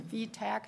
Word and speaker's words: VTAC: Okay, but Adams did say that when the signal goes off, what is a VTAC? Is that how it's pronounced? – Yeah, it's a ventricular tachycardia alarm.